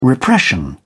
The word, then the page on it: repression: British English